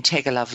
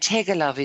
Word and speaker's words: Teggelove: And Tegelov is spelled T-E-G-G-E-L-O-V-E.